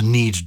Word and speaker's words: knead: Kneading is with a K, isn't it? To knead dough, yeah. – K-N-E-A-D. – Mm. That's Germanic borrowing. And if you remember with those Germanic words, we did pronounce the K. So it would have been, the German is kneten, and we would have talked about kneading, which I love. And we wouldn't have pronounced it exactly like that in Old English, but we would have pronounced the K.